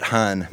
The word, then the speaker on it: Hanh: Uh, if you're interested, looking him up, it's spelled T H I C H. Middle name Nut, N H A T. Last name Han, H A N H.